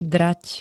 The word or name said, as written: drať